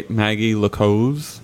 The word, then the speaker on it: Maguy Le Coze: It's Eric Ripert's, four star seafood restaurant owned by Maguy Le Coze. Is that how you say her name? – Yep.